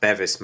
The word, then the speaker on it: Beavis: It's pronounced Bevis, Matt. – You think it's Bevis, John Beavis? Oh, yes. – Well, my brother-in-law has the same surname, okay.